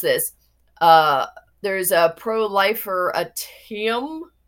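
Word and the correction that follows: proliferatum: and it's Prolifer atum.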